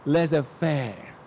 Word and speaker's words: laissez-faire: Everyone speak French. Laissez-faire. Socialan mo pa ng konti. Laissez-faire. Yeah. Okay. Almost silent R. Laissez-faire. Yeah. In French, the French.